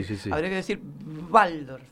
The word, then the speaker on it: Waldorf: Está bien, Waldorf. Igual, nosotros, claro, bueno, no, sí, es sí, que sí. Nosotros pronunciamos así, está bien, yo digo Waldorf porque sé que se dice como B, pero...